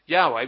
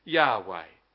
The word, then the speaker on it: Yahweh: And that special name, we think, was pronounced Yahweh. We don't really know because no one for many, many years had actually pronounced it. Because they didn't want to take the name of the Lord their God in vain. And so they didn't, the best way to avoid that, to not ever use the name. And so people forgot how to pronounce it, but we think it's pronounced Yahweh.